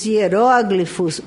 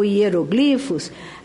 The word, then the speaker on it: hieróglifos: Porque Como a escrita, é gravada para a posteridade, vide, por exemplo, os hieróglifos ou hieroglifos, a nossa ortoepe ainda não se decidiu pela pronúncia dessa palavra, mas vejamos os hieroglifos egípcios do Egito Antigo, ou os manuscritos do Mar Morto.